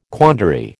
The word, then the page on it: quandary: American English